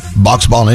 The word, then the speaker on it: Boxbollen: Box Ball. And it's spelled B-O-X-B-O-L-L-E-N.